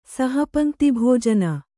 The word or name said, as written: ಸಹಪಂಕ್ತಿಭೋಜನ